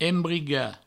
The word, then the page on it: embrigar: embriga